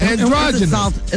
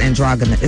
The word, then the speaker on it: androgynous: It is A-D-A-N-D-R-O-G-Y-N-O-U-S. Androgynous. – It's a soft G, though. You said androgynous. It's androgynous.